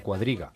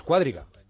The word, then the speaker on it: cuadriga: he descubierto que Juan Ramón Lucas pertenece a esa mayoría de españoles que pronuncia mal cuadriga. Es cuadriga, claro. – Es cuadriga, y creo que lo he pronunciado así, él pone cuadriga con acento, entonces es cuadriga, no cuadriga.